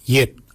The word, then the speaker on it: yed: Nanak is spelled N-A-N-G-A-G, Nanak. Nanak is your neck. Chin, we say yet. Yet for chin. Yet is spelled Y-E-D, yet. Yet is your chin.